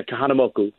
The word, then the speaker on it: Kahanamoku: So if you know Spanish, and like how the Spanish vowels go, you can usually pronounce anything in Hawaiian or Tongan or all of us, because it kind of follows the same rule. So yeah, Kahanamoku.